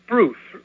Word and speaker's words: Bruce: And my first name is Bruce, rhymes with the spruce moose truce.